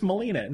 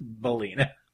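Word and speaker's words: Billina: Because at first I thought she was mispronouncing. I was like, oh, that's clever. It's Melina. No, it's Belina.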